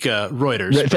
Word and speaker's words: Reuters: Reuters, actually, if I'm spelling it correct or saying it correctly, actually, I think they're about the most non-biased. They're pretty good at just giving you the news. – Most people think, Reuters. – There you go.